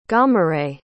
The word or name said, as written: Gahmuret